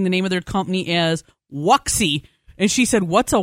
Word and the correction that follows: Wuxi: She goes, "In China, an X is SH, it's Wuxi." I'm like, "Oh, sorry."